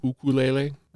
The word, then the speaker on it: ukulele: And did you know that the actual pronunciation is ukulele? – I did not know that. – Yeah, that's the Hawaiian.